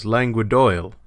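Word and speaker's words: Langue d'oïl: and he pronounced the Northern dialect as Languedoil, which is how it's spelled, O-I-L.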